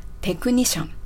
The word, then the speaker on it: technician: そして発音面ではですね、テクニシャンの T の音、すごく英語らしく勢いがついて発音されていたんですけれども、これお気づきになられましたでしょうか。カタカナ発音とね、ちょっと比較してみたいと思います。テクニシャンですね。カタカナではテクニシャン。英語ではテクニシャン。 – テクニシャン。